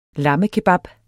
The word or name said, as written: lammekebab